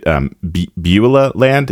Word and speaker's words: Beulah Land: Is it Beulah Land? Is that the right pronunciation? Okay, cool.